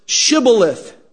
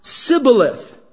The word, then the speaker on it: shibboleth: But the dialect spoken by the men of Ephraim made it difficult for them to pronounce that sh sound. And so it would always come out sibboleth.